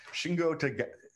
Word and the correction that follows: Shingo Takagi: You said it right the first time. Shingo Takagi.